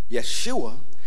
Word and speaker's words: Yeshua: Yeshua is how you say Jesus' name in Hebrew.